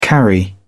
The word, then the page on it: carry: British English